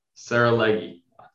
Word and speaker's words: Saralegui: is think of a girl named Sarah with long legs. Sarah Leggy. – Saralegui.